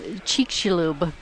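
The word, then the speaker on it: Chicxulub: Cheekshilub.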